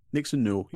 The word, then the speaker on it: Nixon Newell: It talks about her relationship with Tegan Knox or Nixon Newell. Did I pronounce that correctly? – Nixon Newell, yeah, spot on.